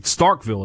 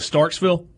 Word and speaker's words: Starkville: Starkville is how you pronounce the name of the city. – Does he go with Starksville? – He went with Starksville.